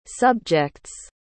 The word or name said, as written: subjects